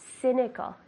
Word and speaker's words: cynical: How do you say cynical in Spanish? – Cínico. – Good, yeah, so the same, but just the C. Cynical, like an S.